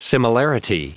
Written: similarity

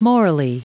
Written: morally

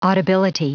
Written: audibility

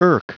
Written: irk

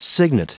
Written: cygnet

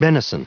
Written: benison

